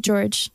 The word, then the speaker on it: Gyorgy: And I'm like, yeah, that's my name, Caity George. He's like, it says Gyorgy. I'm like, it's pronounced George.